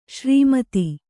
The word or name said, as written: ಸರಮುತ್ತು